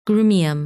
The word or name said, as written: Grumium